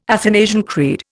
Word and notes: Athanasian Creed: Indian

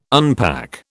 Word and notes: unpack: British English

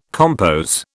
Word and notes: compos: British English

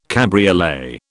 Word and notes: cabriolet: British English

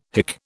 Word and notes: hick: British English

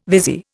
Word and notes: vizier: Indian

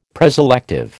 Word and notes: preselective: American English

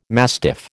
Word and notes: mastiff: American English

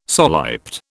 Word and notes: soliped: British English

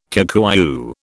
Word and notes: Kikuyu: British English

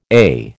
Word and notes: AIH: American English

